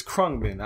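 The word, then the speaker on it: Khruangbin: Well, before I say this, I will say that I pronounced the name wrong. It's Krungbin. I said it wrong on the podcast. Krungbin. – Oh, okay. – That's how you pronounce the name of that group.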